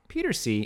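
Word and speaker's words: Pieterse: Oh, no, I think it's pronounced Peters. – Oh, is it? – Yeah, oh. – That's how Ant was pronouncing it.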